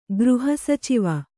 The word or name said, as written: ಗೃಹಸಚಿವ